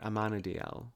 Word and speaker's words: Amenadiel: Amenadiel. Anyways, he's hot. – Yes, you've got him. You got a couple hot ladies. So yeah, I mean, you are. – DB Woodside plays Amenadiel.